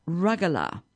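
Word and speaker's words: rugelach: Well, this one that I've chosen, and because it had an unusual name, and it's I'll spell it because it, you don't say it how it's spelt. And it's spelt U-R-U-G-E-L-A-C-H, and it's apparently pronounced rugala.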